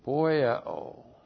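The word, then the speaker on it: poieo (ποιέω): Poieo. Looks like that in the Greek, P-O-I-E, long O, poieo.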